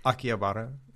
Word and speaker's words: Akihabara: Akihabara.